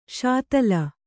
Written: ಶಾತಲ

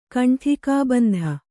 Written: ಕಂಠಿಕಾಬಂಧ

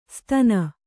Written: ಸ್ತನ

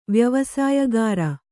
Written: ವ್ಯವಸಾಯಗಾರ

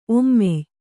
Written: ಔಮ್ಯ

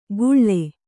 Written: ಗುಳ್ಳೆ

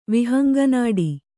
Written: ವಿಹಂಗನಾಡಿ

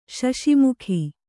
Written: ಶಶಿಮುಖಿ

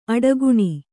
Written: ಅಡಿಗಾಣ್

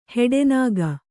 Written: ಹೆಡೆನಾಗ

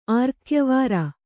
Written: ಆರ್ಕ್ಯವಾರ

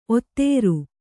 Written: ಓತಾರಿ

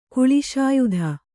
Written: ಕುಳಿಶಾಯುಧ

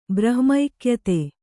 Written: ಬ್ರಹ್ಮೈಕ್ಯತೆ

